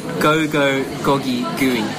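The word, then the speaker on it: gogi gui: The name is pronounced Gogo Gogi Gooey.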